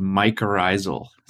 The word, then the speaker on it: mycorrhizal: That association is called mycorrhizal. – Mycorrhizal. – So, yeah, and if we breaking that word down into word roots, mycor is fungus, rhizal is root.